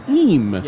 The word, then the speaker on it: him: Eem. – Yeah, there's the word him, H-I-M, is pronounced E-E-M.